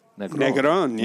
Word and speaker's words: Negrón: This is the second episode recorded from the Orpheum Theater in San Francisco with Hamilton actor Rick Negrón. – Negrón. – I like a little accent there. – Yeah, once I got an accent on the O on the last syllable there.